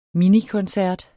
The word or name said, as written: minikoncert